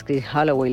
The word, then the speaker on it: Chris Holloway: muchísimas gracias, Chris Holloway. Lo he dicho bien.